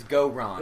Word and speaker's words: Gorons: It's Gorons.